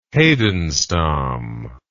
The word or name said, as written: heidenstam